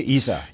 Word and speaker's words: ESA: That's the right way to pronounce it, right? ESA in English? – I like to say ESA.